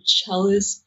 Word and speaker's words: cellist: And she plays a cello, and like, she is a mean celloist. I said it right? – Yeah. – Like, no, sorry, go ahead. – Sure, cellist, it doesn't matter.